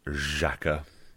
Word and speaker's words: Xhaka: I think that is someone, by the way, who should be pronounced Xhaka. I think that is a Zh.